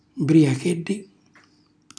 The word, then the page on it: mbriachelli: Calabrese